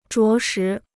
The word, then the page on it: 着实: zhuó shí